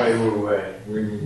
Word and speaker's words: Wei Wu Wei: Wei Wu Wei. – Wei Wu Wei is how you pronounce it? Okay.